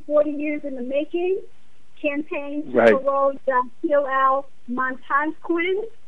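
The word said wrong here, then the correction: Muntaqim: I think that's Montakim is how you pronounce his last name.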